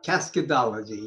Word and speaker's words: Cascadology: But you also discovered something else called Cascadology, how do you pronounce? – Cascadology, yes.